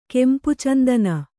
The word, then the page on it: ಕೆಂಪುಚಂದನ: Kannada